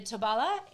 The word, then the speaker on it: Tobala: And the Tobala is, did I say that right? – Yeah.